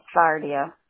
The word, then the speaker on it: Sardia: Sardia. – Could you spell that? – S-A-R-D-I-A.